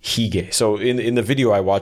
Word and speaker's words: hygge: Are you familiar with the Danish term hygge? I think it's spelled H-Y-G-G-E. – Hygge. – Or maybe hygge.